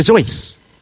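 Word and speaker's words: Seuss: Dr. Zeus. Rhymes with voice, not Dr. Zeus.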